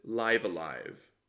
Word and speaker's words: Live A Live: They pronounce it live alive so rhymes with dive a dive or chive or hive or clive.